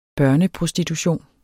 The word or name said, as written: børneprostitution